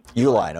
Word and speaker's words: Uihlein: And on top of that, we've got the Supreme Court apparently dancing to the tune now of this Illinois industrialist, Richard Uihlein, is it how it's pronounced? He's the guy who... – Uihlein, okay.